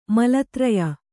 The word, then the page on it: ಮಲತ್ರಯ: Kannada